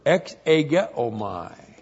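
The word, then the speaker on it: exegeomai (ἐξηγέομαι): And that translation of he has made him known is the verb exegeomai. E-K-S, long E-G-E-O-M-A-I.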